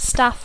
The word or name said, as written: staff